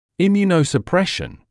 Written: immunosuppression

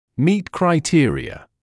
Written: meet criteria

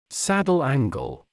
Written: saddle angle

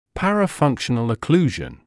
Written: parafunctional occlusion

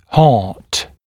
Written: heart

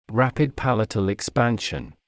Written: rapid palatal expansion

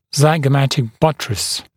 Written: zygomatic buttress

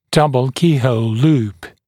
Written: double keyhole loop (DKH, DKHL)

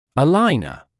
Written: aligner

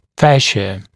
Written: fascia

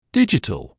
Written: digital

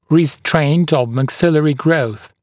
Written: restraint of maxillary growth

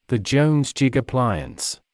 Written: the Jones jig appliance

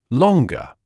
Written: longer

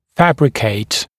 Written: fabricate

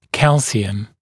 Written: calcium

